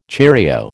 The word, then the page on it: cheerio: American English